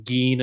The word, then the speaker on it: Gein: I think somebody used the pronunciation Gein, and he said like, that's fine.